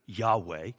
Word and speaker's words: Yahweh: It's all consonants, by the way. Y-H-W-H. We pronounce it Yahweh.